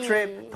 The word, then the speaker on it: trip: 자, T-R-I-P, trip 자체가 네. 여행이죠. 발음할 때 트립 아니죠, 여러분들. 네? TR, 이 충돌을 하게 되면 치읓 비슷하게 됩니다. 그러니까 입모양을 모으고 한다는 느낌으로 다시 한번 가볼게요. Trip. There you go.